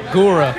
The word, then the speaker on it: Gura: Gura. – Gura. – Yeah, like guru, but with an A.